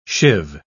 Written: shiv

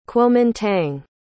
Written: Kuomintang

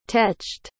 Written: teched

